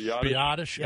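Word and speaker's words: Biadasz: Biotish.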